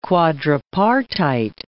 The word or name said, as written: quadripartite